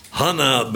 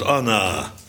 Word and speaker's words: Hanna: Ja, die heette Hanna, bijgenaamd Anna. Afhankelijk van of je de H wil uitspreken of niet.